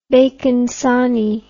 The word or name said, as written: bacon sarnie